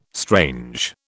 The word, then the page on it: strange: British English